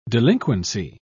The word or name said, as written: delinquency